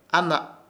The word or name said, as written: AHNA